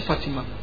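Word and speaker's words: Fatima: به اسم معجزه بانوی ما فاطیما یا فاطمه، چون تلفظ انگلیسی فاطمه میشه فاطیما، فاطیما تلفظ میشه.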